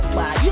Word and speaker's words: okpa: Are you calling it okpa or okpa? – How is it pronounced? – It's okpa.